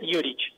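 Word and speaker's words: Jurić: Sí, es Alexei Juric. Porque viste que cuando es apellido croata, viste, cuando termina en C, termina como CH.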